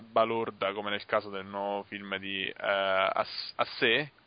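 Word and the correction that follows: Assayas: che con le pronunce francesi? – Assaya.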